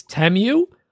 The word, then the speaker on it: Temu: He had a deal with them, and they apparently sent the audio file of how to pronounce it, and it's Temu.